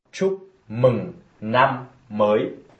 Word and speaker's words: Chúc mừng năm mới: Ça se prononce ainsi. – Chúc mừng năm mới.